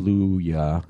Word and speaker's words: Kaluuya: This is a tough one, too, because I feel like Daniel Kaluuya, if I'm pronouncing the name. – Kaluuya, yeah.